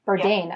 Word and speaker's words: Bourdain: Anthony Bourdain. – Yeah, Bourdain.